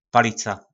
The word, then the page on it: Palica: Po našimu